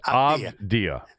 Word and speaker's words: Avdija: It's Avdija. I believe that's Avdija. How it's pronounced. That's the way I would pronounce it.